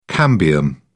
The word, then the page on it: cambium: British English